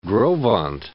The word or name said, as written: Gros Ventre